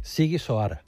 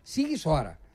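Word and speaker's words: Sighișoara: ¿Cómo se dice? – Siguisoara. – Siguisoara.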